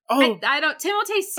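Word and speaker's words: Timothée: Excuse me. It's Timotei. – Please tell me that's not true. – No, it's true. I saw an interview with him. He's like, yeah, my name's actually pronounced Timotei, but I don't correct people because it happens so much.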